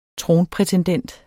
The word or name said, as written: tronprætendent